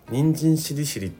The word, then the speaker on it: 人参しりしり: 人参しりしりってあのレシピあって、そういう感じで言われてるんですけど、沖縄のイントネーションでは人参しりしりなんですよ。しりしりってちょっと下げるんですよ。人参しりしりじゃなくて人参しりしりっていうイントネーションなんですけど、